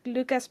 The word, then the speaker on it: Lucas: And the other character, like, kind of like a proper introduction for, it's supposed to be like Lucas Prouse, but it is like pronounced like Luca in the game.